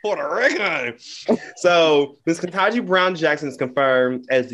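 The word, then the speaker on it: Ketanji: So, today was confirmed that Miss Kentanji, I said her name right. – Yeah. – Okay, I've been messing her name up. I just been calling her Miss Jackson, okay? Because I've been messing her name up. Because I seen somebody else call her Kenyatta. – Not Kenyatta. – I see. That is not that woman's name. – That ain't even close.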